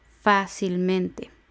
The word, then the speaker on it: fácilmente: Fácil lleva el acento en la A de fa, sería fácil. Por tanto, fácilmente también lleva acento en la a de fa. Son cuatro sílabas, la última es te, la penúltima es men, la antepenúltima es sil y la anterior a la antepenúltima es fa, fácilmente.